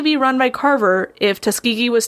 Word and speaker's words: Tuskegee: And this was at the Tuskegee Institute, now Tuskegee University in Alabama. And I had to learn how to pronounce Tuskegee for this episode, because my brain was like, it's Tuskegee. It's not. – It is not, no.